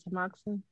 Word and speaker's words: tamoxifen: They also wanted me to take, I don't want to say it correctly, tamoxifen.